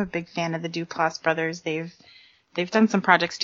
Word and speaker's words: Duplass: HBO has canceled the comedy is it the Dunlap brothers, or how do you say Duplass.